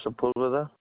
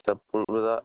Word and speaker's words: Sepulveda: Edward Sepulveda. – Can you pretty please spell that for me? – E-D-W-A-R-D. Last name is Sepulveda, S-E-P-U-L, V like Victor, E-D-A.